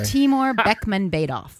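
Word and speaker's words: Bekmambetov: Is that how you pronounce Beckmembetal? – I would never pronounce it that way. – Timur Beckmembetal is his name.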